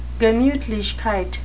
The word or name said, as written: gemutlichkeit